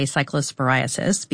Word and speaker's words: cyclosporiasis: If you are worried, there is a CDC cyclosporiasis, I didn't pronounce that right, outbreak. Cyclosporiasis. Yes.